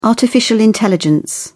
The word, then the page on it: artificial intelligence: British English